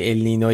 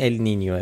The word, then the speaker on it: ال نینیو: به هر حال اینو همین اول بگیم که اگه دیدید یه بار میگیم ال نینو یه بار میگیم ال نینیو، علتش اینه. ولی تلفظ درست اگه میخواین ال نینیو، ولی همه جا تو فارسی بچرخید کسی چیزی نمیفهمه.